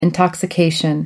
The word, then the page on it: intoxication: American English